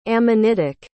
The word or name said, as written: ammonitic